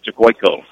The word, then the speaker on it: Tiocuyco: He spells Cicoico, T-I-O-C-U-Y-C-O. But that's how do you pronounce it? He said Cicoico. So that's the way we'll say it.